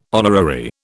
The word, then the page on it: honorary: British English